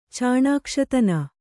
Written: ಚಾಣಾಕ್ಷತನ

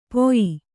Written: ಪೊಯಿ

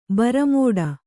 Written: ಭಾರಿಮಾಡು

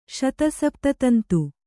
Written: ಶತಸಪ್ತತಂತು

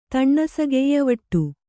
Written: ತಣ್ಣಸಗೆಯ್ಯವಟ್ಟು

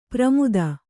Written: ಪ್ರಮುದ

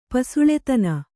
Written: ಪಸುಳೆತನ

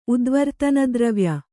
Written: ಉದ್ವರ್ತನದ್ರವ್ಯ

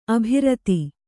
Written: ಅಭಿರತ

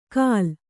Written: ಕಾಲ್

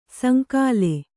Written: ಸಂಕುಲ

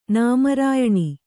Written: ನಾಮರಾಯಣಿ

